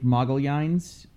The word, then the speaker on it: Magalhães: My name is Elliot Magalhães, Magalhães being the original Portuguese pronunciation of the anglicized Magellan.